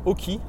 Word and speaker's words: Aukey: Donc la marque Oki, donc OK hein, pour les. On va le prononcer à la française, ça s'écrit A-U-K-E-Y.